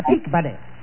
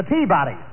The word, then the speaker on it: Peabody: At the peak, buddy. – Yeah. Wait a minute now. You've got the wrong pronunciation. It's the Peabody.